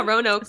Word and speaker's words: Roanoke: Roanoke. Am I pronouncing that right? – Mm-hmm. Roanoke? Yeah, Roanoke.